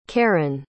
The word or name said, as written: karin